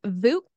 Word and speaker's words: Vuk: It is Vuk, spelled with a V, V-U-K. It is Slavic, meaning wolf, because he's a Maine Coon.